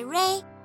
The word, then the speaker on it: re: 再来 ray 跟光线 ray r a y 是一样的发音，所以唱 ray a drop of golden sun 一道金色的太阳光芒。